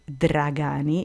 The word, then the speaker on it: Dragani: Viviana, it's V-I-V-I-A-N-A. And Dragani, which is pronounced actually Dragani in Italy, it's D as in David, R-A-G-A-N-I.